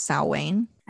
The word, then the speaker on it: Samhain: Do you know that Samhain is pronounced Samhain? – I do now. – Yep. It's because it's Irish and they can't spell anything how you say it.